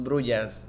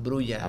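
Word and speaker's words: Brujah: No, y no es no son brujas, no son brujas, son Brulla.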